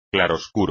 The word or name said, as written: claroscuro